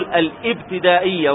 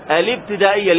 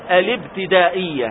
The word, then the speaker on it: الابتدائية: نقول الابتدائية لأن أل التعريف من الأشياء التي تقدمت همزة الوصل، وأي شيء يتقدم همزة الوصل فإن همزة الوصل تحذف في النطق وتثبت في الكتابة. فنقول الابتدائية هكذا، الاب بدون همزة تماما من اللام إلى الباء مباشرة، الابتدائية.